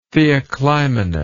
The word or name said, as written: theoclymenus